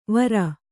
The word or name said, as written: ವಾರು